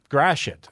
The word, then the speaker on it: Gratiot: It's spelled G-R-A-T-I-O-T and it is pronounced... – Gratiot? – Oh. – Gratiot. – Gratiot. Okay. – Gratiot. Yes, it is the undoing of many of an announcer when they come in from outstate.